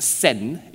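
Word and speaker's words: sen (戦): This character is pronounced sen in Japanese and is equivalent to the Chinese word zan, zan zhen de zan.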